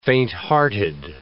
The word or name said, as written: fainthearted